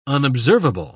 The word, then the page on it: unobservable: British English